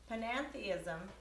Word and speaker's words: panentheism: Panentheism, did I say that right? – Yes, good.